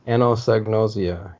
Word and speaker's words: anosognosia: And I'm going to butcher the pronunciation of that, but is it Anosognosia? – Anosognosia, yeah, right.